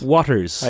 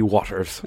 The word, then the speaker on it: Watters: I'm going to jump in on you there, and ask, is his name Waters and you're comically mispronouncing it? No, W-A-T-T-E-R-S. – Waters. – I think it would be Waters.